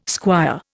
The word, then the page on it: squire: Indian